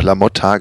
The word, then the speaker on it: LaMotta: Es muss, muss mit A ausgesprochen werden. Also mein, meine äh, These ist äh, hinfällig. Also Französisch Motte geschrieben Mod, ja, aber im, also im Italienischen, was sehr logisch klingt, ähm, müsste er dann noch La Motta ausgesprochen werden.